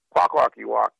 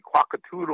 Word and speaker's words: Kwakwaka'wakw: I got sick and tired of non-Indians mispronouncing Kwakwaka'wakw, my tribe, and calling me Kwakatoodle or something, and they couldn't pronounce Chonakwa.